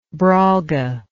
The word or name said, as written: brolga